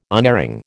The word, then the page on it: unerring: American English